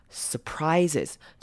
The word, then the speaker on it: surprises: Surprises，之前好像前几个礼拜的也有提过这个字 surprises 的念法。 That is what surprises us. Surprises us. 好，surprises 记得后面 s 念成 z 的声音。